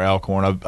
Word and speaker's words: Alcorn: And I always forget if it's Alcorn or Alcorn.